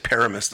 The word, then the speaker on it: Paramus: I said it right this time, right? I think I said Paramus the first time. – Absolutely. Yes, you did.